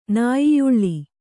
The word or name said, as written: ನಾಯಿಯುಳ್ಳಿ